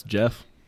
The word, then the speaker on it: Geoff: I know at least the second one, that's Jeff. So I know that one's how to pronounce that, the second name, Geoff Johns. – Huh, Jeff with a G.